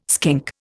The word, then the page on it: skink: Indian